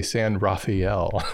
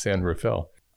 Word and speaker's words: San Rafael: You can always tell people from out of town because they say San Rafael. That's not how you pronounce it. It's San Rafael.